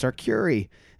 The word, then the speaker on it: Arcuri: That's, I have a bit about, I'm not going to do a bit, but I have a bit about how, like, an old Italian man told me I was pronouncing my name wrong. So yeah. – I'm like, it's Arcuri.